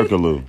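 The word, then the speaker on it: Türkoğlu: How do you say Turkoglu.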